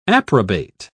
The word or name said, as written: approbate